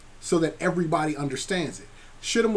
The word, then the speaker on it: Shittim: There's a passage in the Bible, forgive me for not knowing it immediately, that talks about the land of Shittim. That's how it's pronounced.